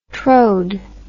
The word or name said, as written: trode